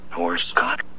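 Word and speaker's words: Horscoch: Poor Scott. – Scott? – No. Poor Scott.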